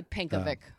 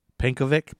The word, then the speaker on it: Pencovic: Probably Pinchovic is how you say it. – Probably Pinkovic. – Pinkovic, P-E-N-C-O-V-I-C, Pinkovic, like Yankovic.